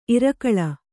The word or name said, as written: ಇರಕಳಿ